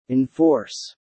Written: enforce

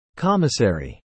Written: commissary

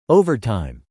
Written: overtime

